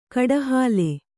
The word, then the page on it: ಕಡಹಾಲೆ: kaḍahāle